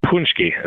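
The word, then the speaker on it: Pączki: Yeah, Punchki season begins today, Fat Thursday, when Polish people traditionally eat Punchki. Is that the right way to say it? – Punchki is what I've always called it.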